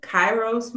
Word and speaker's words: Kairos: I'm thinking that's what you mean when you refer to the Kairos moment.